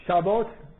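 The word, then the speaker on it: شبات (שבת): ده دقیقه وقت داریم ده دقیقه تمام میگه شبات شبات یعنی لفظ یهودیشه تو قرآن مثلا سبت اومده سبت بهش میگن شیبس الان انگلیسی ها اینجوری میگن شبات تلفظ ابریش شبات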